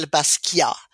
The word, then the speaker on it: Basquiat: And this is, I don't know how to pronounce his last name. Is it Basquiat? – Yes. You would say, with a French accent, you would say Jean-Michel Basquiat, who was an amazing artist.